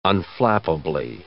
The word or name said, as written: unflappably